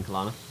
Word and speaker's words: Kalana: While they're heading off to Northshire, what are you doing, Kalana? – Just checking the place out.